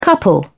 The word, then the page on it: couple: British English